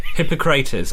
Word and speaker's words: Hippocrates: And then, what's his name? Hypocrisy? I don't know how to say his name. – Hypocrisy. – Hippocrates, I think. – Yes. Is that guy. Yeah. Hippocrates.